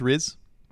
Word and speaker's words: Riz: Is that how it's pronounced? Riz? – Yeah. – Mm-hmm. Okay. I was never sure.